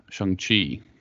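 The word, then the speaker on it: Shang-Chi: Shang Chi, yeah. – Uh, Shang Chi. I love how they took about three minutes of your time to explain the proper pronunciation. I'm glad they did because a lot of people are just like Shang Chi, Shang Chi, Shang Chi. I, I overheard somebody call him Xiang.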